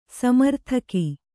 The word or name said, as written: ಸ್ಮರೋದಕ